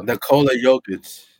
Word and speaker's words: Nikola Jokic: Nikola Jokic.